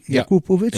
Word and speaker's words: Jakupovic: Wie, Jakupovic? Ja. Ah ja, gut, dann habe ich das ja richtig ausgesprochen. Ja. – Na, verrückt. – Es ging ja um eine Betonung dabei.